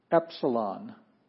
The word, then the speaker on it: Epsilon: Epsilon, a short E sound, not a long E, just a short E epsilon.